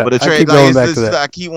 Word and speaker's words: Atreus: I could I can't even pronounce his fucking name right. – Atreus. – Now. Atreus. Atreus. – Atreus. – I keep wanting to say Atreyu.